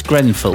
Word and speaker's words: Grenfell: It's Grenfell.